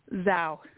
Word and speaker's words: Zhao: Your last name is pronounced what? – Zhao. – Zhao. Wow, you got it pretty close.